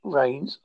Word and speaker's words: Raines: Rains is spelled with RAINES. – Hold on, hold on. What is it? – Right, it's Mark Anthony Rains, RAINES, aka Ghostman.